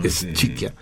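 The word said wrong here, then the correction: Schicchi: Skiki. – Skiki. Es es, ¿Qué, qué, que haga? – Kiki, es que no sé cómo se pronuncia eso. – Sí, así es Kiki.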